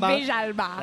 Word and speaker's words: Villalba: Like, is that right? Villalba? – Villalba. – That's how they say it on the Spanish radio.